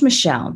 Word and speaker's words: Mixel: It's pronounced Michelle, but it's spelled a little funny.